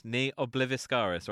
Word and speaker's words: Ne obliviscaris: And I think, you know, I always struggle pronouncing the second word. Ne obliviscaris, right? That's how you say it. – Yeah, that's on the money, yeah.